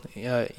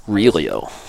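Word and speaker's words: Hélio: It's spelled with an H. When every other Gracie is an R, pronounce it, because in Portuguese you pronounce R as H's. – That's funny. – So it's like a Spanish J. But for some reason, Helio is actually spelled with an H. I don't know why. You'd think it was Relio.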